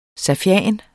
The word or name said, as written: safian